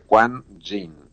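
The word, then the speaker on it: Kwan Yin: Todas las personas que quieren pareja o se quieren casar van a buscar una figura de la deidad Quan Jin. Se escribe con K y con Y a la segunda parte de su nombre, Quan Jin. Se escribe K-W-A-N y luego Y-I-N.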